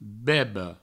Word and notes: beba: Provençal; bebo

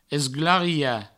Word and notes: esglariar: provençal; esglaria

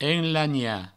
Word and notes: enlanhar: enlagna; provençal

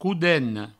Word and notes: codena: coudeno

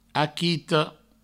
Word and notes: aquito: aquitou

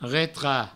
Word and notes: retrach: retra; Provençal

